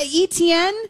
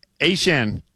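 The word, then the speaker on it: Achane: And so apparently, A-Chan, who was formerly, the artist formerly known as ETN, says that it's always actually been A-Chan, but we've always been pronouncing it wrong with ETN.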